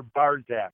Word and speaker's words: Barczak: It'd be like Tom Zach or Barczak, the same way as a football player.